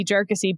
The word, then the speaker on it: Jarkesy: And lo and behold, man, the oral advocate for Jarkusi stood up and corrected the chief. – Wow. – Because he knows that podcasts like ours, you know, really rely on that initial pronunciation. So we will keep pronouncing it SEC v. Jarkusi based on counsel, and we will then ignore the Chief Justice.